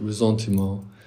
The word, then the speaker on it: ressentiment: Resentiment, so it's spelled a little differently from resentment, the English resentment. It's a French word, resentiment.